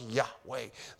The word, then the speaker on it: Yahweh: That is rendered in Hebrew is YHWH, which we pronounce Yahweh.